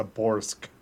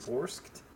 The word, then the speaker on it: borscht: It's a borsk. – Is that how you say it? – Yes. Come on, dude.